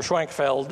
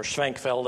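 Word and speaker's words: Schwenckfeld: Schwenkfeld, Caspar Schwenkfeld, or Schwenkfeld, I guess is the way they would have pronounced it in German, pointed out that the establishment of the new church had failed to result in spiritual and moral betterment of the people.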